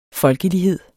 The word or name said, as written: folkelighed